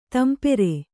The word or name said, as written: ತಂಪೇರು